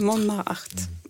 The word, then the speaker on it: Montmartre: Yes, this is kind of hard to pronounce, but Amélie from Montmartre. – Montmartre.